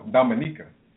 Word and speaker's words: Dominica: I said Dominica. – No, Dominica. – It's spelled D-O-M-I-N-I-C-A. – Yeah, but it's not Dominican Republic, though. It's Dominica.